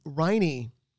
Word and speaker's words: Riney: Riney rhymes with Heine.